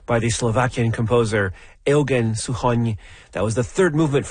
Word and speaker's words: Suchoň: It's S-U-C-H-O-N, and the N has a hatchet on it, which is why it's Suchoň.